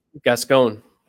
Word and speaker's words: Gascon: Gascon.